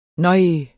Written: nøj

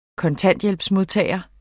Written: kontanthjælpsmodtager

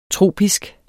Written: tropisk

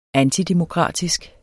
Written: antidemokratisk

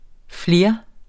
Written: flere

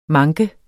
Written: manke

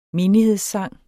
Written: menighedssang

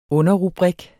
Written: underrubrik

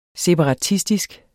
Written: separatistisk

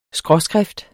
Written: skråskrift

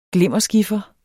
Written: glimmeren

